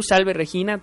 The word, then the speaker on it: Salve Regina: Salve Regina, como lo pronuncias tú, Salve Regina también está correcto.